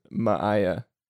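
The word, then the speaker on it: Maia: my, my, No, it's Maya. I, they said it in the thing, and I actually like listened to it several times, so I would pronounce it right, and I can't say it for some reason. Maya.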